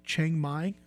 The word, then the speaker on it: Chiang Mai: And the city is called Chiang Mai. Is that how you say it, Paul? – Yes.